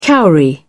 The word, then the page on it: cowrie: British English